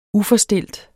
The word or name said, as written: uforstilt